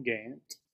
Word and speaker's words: Gant: My name is Nicholas Ryan Gant, as you said before, and you said it correctly, which I do appreciate. A lot of people get the Gant mixed with Grant, which is it's a thing.